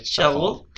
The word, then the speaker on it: Charroux: Es el libro de los mundos olvidados de Robert Charroux, así se escribe, Charroux, la verdad es que es un apellido francés, cheriach. Shago. Ándale. – Shago. – Shago. Lo escuché como gangoso, pero así hablan los franceses.